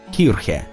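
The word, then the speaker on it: Kirche: «Вы произносите это слово так, будто оно пишется «к-ир-к-е». Там нет звука «к».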